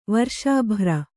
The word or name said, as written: ವರ್ಷಾಭ್ರ